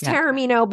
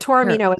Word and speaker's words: Taormino: Well, it's Taormino, but Taormino is way closer than a lot of people get.